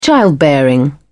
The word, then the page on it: childbearing: British English